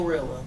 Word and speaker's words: GloRilla: There's pros and cons, because if my thing was personal assistant and I'm riding with Gorilla, is that how you say her name? – Glorilla. – Glow? – Yeah. G to the L to the OG Glow.